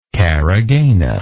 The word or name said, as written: caragana